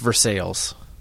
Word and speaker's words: Versailles: There's a town in Kentucky spelled like Versailles, like the Palace of Versailles. It's pronounced Versailles.